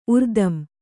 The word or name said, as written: ಉರ್ದಂ